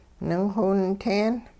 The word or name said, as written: ningxiwinteen